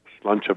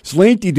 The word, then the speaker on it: Sláinte: And we bring onto the phone lines from somewhere in Central Sioux Falls my weird friend, the common man, Sláinte Common. – Sláinte back at you. That's how you're supposed to say it? God, I've been saying it wrong all these years.